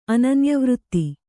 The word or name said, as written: ಅನನ್ಯವೃತ್ತಿ